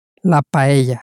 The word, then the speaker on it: paella: Again, the double L at the end of paella makes this same y sound. So in Spanish, if you do come across any double L's, you can know that they are pronounced y and not l.